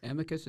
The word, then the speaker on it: Amicus: Amicus, how do you say that? – Yes, I'll let you say that. – The friend of medicine. – I got the translation, but my Greek is not sharp. – Amicus is good, yes.